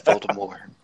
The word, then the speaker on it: Voldemort: You don't pronounce the T, Nick. It's Voldemort.